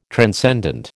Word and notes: transcendent: American English

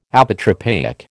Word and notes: Apotropaic: American English

